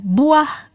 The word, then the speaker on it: buah: And in Malay, we say buah berry. Buah berry. Buah means fruit, berry is berry. So buah we spell B U A H. That's buah. Berry is spelled B E R I. Berry. Buah berry.